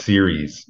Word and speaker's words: Ceres: Ceres is spelled C-E-R-E-S, right? – Yes. Yep. Ceres. Yeah. It's pronounced Ceres, like television series, but it's C-E-R-E-S.